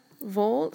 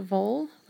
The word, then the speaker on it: Wall: It probably shouldn't be too long, but I just watched a show called Undercurrent, the Disappearance of Kim Vall. So it's spelled W-A-L-L, but I think they were pronouncing it Vall, like a V in English terms.